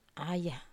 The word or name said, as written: aya